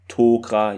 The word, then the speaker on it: Tok'ra: Interessanterweise wird es hier auch richtig ausgesprochen, nicht wie in der vorherigen Folgen Tok'ra. Jetzt ist es hier Tok'ra wieder. Hoffen wir, es bleibt dabei.